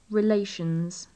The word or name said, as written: relations